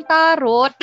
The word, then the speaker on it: tarot: How do you pronounce T-A-R-O-T? – I found an answer. It's displayed on your iPhone. – Ay, ayaw basahin. – Ay, it should din. Oy, sabi niya rin, ano yan, no? Taro. T-A-R-O-H. Taro. – Oh. – T-A-R-O-H pala.